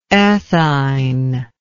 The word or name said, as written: ethine